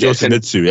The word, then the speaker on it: Yoshimitsu: Yeah, it's Yoshimitsu.